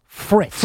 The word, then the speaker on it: Fritz: Well, you certainly don't because you keep saying his name wrong. It is Jack Fritz. – Fritz, okay. Fritz. I'm sorry. – F-R-I-T-Z.